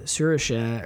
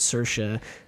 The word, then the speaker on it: Saoirse: And she was explaining to Margot Robbie how to pronounce her name, and she said Saoirse or something like that, kind of how you're saying it, but it's easier for Americans to pronounce it Saoirse. And Margot Robbie looks at her and goes, I don't think I can tell the difference.